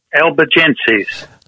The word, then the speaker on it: Albigenses: Albigenses.